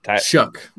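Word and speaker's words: Shough: And that's probably honestly because I've been pronouncing it show. It's not pronounced show. It's pronounced. It's to say it. How would you pronounce it? – Shuck, like if you're shucking a piece of corn. – Okay. Yeah. There's no K, C, K. Okay, but Tyler Shuck, like you shucking corn.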